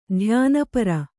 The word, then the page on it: ಧ್ಯಾನಪರೆ: dhyāna pare